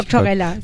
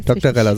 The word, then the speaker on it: Doctorella: Sandra und Kerstin Greta äh, spielen einmal in der Band Doctorella. Ist das richtig ausgesprochen? – Ja. Oder heißt es Dr. Ella? Das habe ich mich nee, nämlich Doctorella. Doctorella, so wie Barbarella quasi.